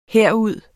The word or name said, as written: herud